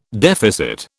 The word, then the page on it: deficit: British English